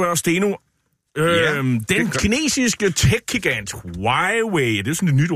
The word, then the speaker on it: Huawei: Huawei, okay. Ja. Der vil bare sige Huawei.